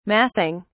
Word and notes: massing: British English

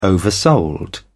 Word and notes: oversold: British English

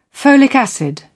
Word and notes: folic acid: British English

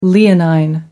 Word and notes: leonine: American English

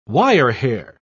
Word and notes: wirehair: British English

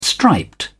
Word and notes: striped: British English

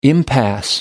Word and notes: impasse: American English

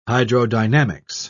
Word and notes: hydrodynamics: British English

